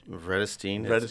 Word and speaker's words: Vredestein: Vredestein.